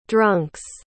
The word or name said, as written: drunk's